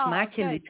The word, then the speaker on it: Maartje Lute: My name is Maartje Lute.